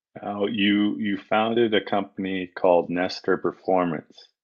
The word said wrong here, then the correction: Nestry: Did I pronounce that correctly? – Yeah, it's actually Nestry.